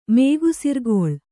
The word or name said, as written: ಮೇಗುಸಿರ್ಗೊಳ್